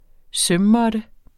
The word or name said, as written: sømmåtte